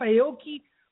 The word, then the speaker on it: Aoki: Aoki. – Say it right, please. Aoki!